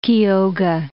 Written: Kioga

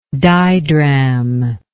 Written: didrachm

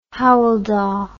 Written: Howel Dda

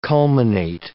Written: culminate

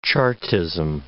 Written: Chartism

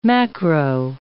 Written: macro

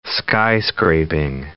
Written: skyscraping

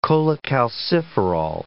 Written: cholecalciferol

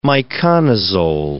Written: miconazole

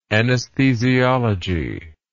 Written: anaesthesiologist

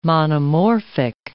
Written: monomorphic

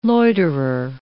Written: loiterer

